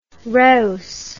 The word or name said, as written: reus